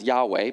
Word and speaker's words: Yahweh: We pronounce that now as Yahweh, but there was never actually any vowels put onto it in the Hebrew. And so when one would try to pronounce it, if you did try and pronounce it, it's just aspirations.